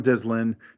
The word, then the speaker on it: Dyslin: Okay, so uh, so my name is John Dyslin.